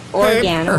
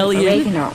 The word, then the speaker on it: oregano: And this is an Italian herb, oregano, or some people pronounce it oregano.